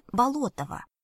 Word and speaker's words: Болотова: «Жизнь и приключения Андрея Болотова, описанные самим им для своих потомков. Мы умышленно ставим ударение на второй слог, именно так его фамилия звучала два столетия назад.